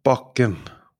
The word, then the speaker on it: bakke: For example, bake, bake, bake, bakke, bakke, bakke, bake, bake, bakke, hill.